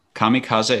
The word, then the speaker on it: kamikaze: Kamikaze. – Sam? Harakiri. – Kamikaze. – Kamikaze is correct, or kamikaze, yes.